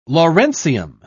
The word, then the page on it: lawrencium: British English